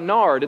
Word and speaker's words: gnar: So I thought, wouldn't it be weird if they're talking about nard? It turns out it's spelled G-N-A-R when you're shredding, right?